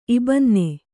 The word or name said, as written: ಇಬ್ಬನ್ನಿ